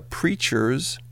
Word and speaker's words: Preatures: Coming up now, Yumi Zuma, The Preachers, spelled like creatures but with a P, and this one from Scottish band Travis, from their 2007 The Boy With No Name album.